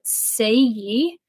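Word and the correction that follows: Sgaeyl: Sigail. – Sigail.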